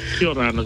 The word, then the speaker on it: Kia orana: Kia ora, ana.